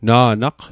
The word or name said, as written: na'naq